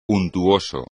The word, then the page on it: untuoso: espagnol